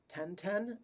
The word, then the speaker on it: Tintin: or I believe in its native French is pronounced Tintin, by the author.